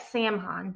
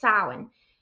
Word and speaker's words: Samhain: It looks like Samhain, but no, it's Samhain.